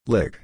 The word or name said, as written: lick